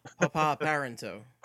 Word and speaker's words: P.A. Parenteau: That name, by the way, is Pa Parenteau. You're pronouncing it. Oh, my bad. – It's Pop. Like, he's a Papa. Papa Parenteau. – Papa Parenteau, yes.